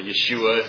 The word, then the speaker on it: Yeshua: Yeshua, if you really want to pronounce it correctly, as we like to anglicize everything.